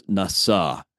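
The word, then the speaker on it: nasa (נסה): So the word is nasa.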